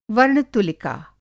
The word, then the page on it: वर्ण-तुलिका: Hindi